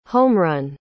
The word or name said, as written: homerun